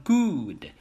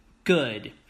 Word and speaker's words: good: 第一个是 good 这个词 good news 非常的简单，但是要注意哈，不要把它读成 good，这是不对的，并不是 oo，而是短元放松音嘴唇，不要用力很轻松读过去 good。